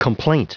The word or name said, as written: complaint